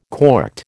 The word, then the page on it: corked: American English